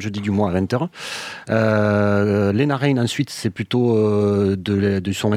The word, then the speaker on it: Lena Raine: Et donc ça, c'est un extrait de ta seconde émission autour donc de Lena Raine, je prononce bien.